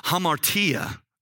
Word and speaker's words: hamartia: I want you to know that the word sin is a Greek word that is called, pronounce it hamartia, okay?